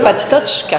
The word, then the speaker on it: Patočka: Vous avez ensuite soutenu une thèse sur le philosophe tchèque Jan Patočka. J'espère que je prononce bien. – Patočka. – D'accord, excusez-moi. Et vous êtes maintenant professeur à l'Institut catholique de Paris en philosophie, morale et politique.